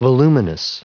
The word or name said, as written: voluminous